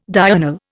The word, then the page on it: diurnal: Indian